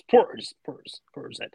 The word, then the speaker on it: Portishead: They're Bristol way, and they so it's Portishead.